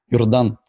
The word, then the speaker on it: Jordan: Now, to introduce myself, as I already mentioned, my name is Jordan. But if you want to address me proper, the original pronunciation is Jordan, which I know it sounds like I am done, but I am just beginning.